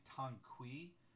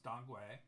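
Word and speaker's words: dang gui: And so bupleurum and dongwe formula, some people will say tangqui formula because it's using the Wei Giles and it's spelled T-A-N-G-Q-U-E-I. So it's actually pronounced dongwe, and the pinyin is D-A-N-G and G-U-I. So it actually, in the pinyin, is spelled a lot closer to how we would pronounce it, dongwe. So even if you see it written tangqui, it is still pronounced dongwe.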